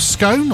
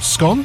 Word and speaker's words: Scone: So in 1296, the Stone of Scone, or Scone, depending on how you pronounce it, on which Scottish kings have been crowned for centuries, was seized by King Edward I of England.